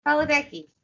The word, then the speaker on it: Padalecki: Padalecki. There you go. – Padalecki. – Yeah. You were saying Padalecki. – Well, right. She keeps on saying I'm Padalecki.